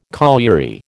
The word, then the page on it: colliery: American English